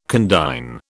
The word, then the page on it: condign: British English